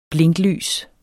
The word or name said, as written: blinklys